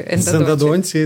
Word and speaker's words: эндодонтии: Мише, я правильно ударение ставлю. Эндодонтии. да. да.